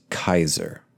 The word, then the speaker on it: Caesar: I had a good-sized Caesar salad, or Kaiser salad, if you want to pronounce it correctly. Yes, Caesar, like Julius Caesar, that word is actually pronounced Kaiser.